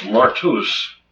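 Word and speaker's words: martus (μάρτυς): The word witness, as it's mentioned here in chapter 12, compassed about the great cloud of witnesses, comes from a Greek word pronounced martus, and our word martyr comes from that.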